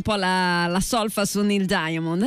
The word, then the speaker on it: Diamond: Diamond.